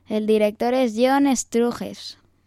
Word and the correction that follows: Sturges: Más o menos. – Hombre, a ver, como no sabemos cómo se pronuncia, está pronunciada en castellano. – Struges, ¿no? – John Sturges, que también dirigió La Gran Evasión.